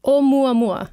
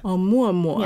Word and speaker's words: Oumuamua: So this interstellar object, Oumuamua. Am I saying that right? – Oumuamua. – Oumuamua.